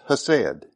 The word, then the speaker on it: hesed: but it's hesed, H-E-S-E-D.